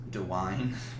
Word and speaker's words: Dwyane: DeWine. – That's how it's spelled. It's like D-W-Y-A-N-E.